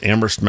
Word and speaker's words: Amherst: That band, Dinosaur Jr., started up in a town that is my old stomping grounds, Amherst, Massachusetts. Please don't pronounce the H.